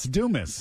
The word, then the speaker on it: Dumas: I can't pronounce it. It's D-U-M-A-S. – It's Dumas. – Dumas. There we go.